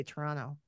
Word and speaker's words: Toronto: And that's how you say it. You say Toronto. You just kind of like slur it all together.